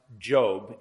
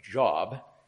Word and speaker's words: Job: And yes, the title is pronounced Job, though it looks like Job.